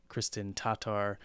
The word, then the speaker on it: Kristen Tatar: And yes, we are going to attempt to pronounce it correctly now moving forward. Kristen Tatar. Hopefully that's a little bit closer for you, Kristen.